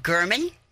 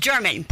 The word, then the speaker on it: German: Is currently on trial for the murder of a journalist in Las Vegas named Mr. German. – Mm-hmm. – You think it was German, but it's not. It's a hard G.